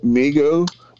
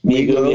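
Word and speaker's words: Mi-Go: Now, I've often called it the Migo, but I think it's the Migu, maybe, you know, the fungi from... – I pronounce it Migu. – Migu, yeah.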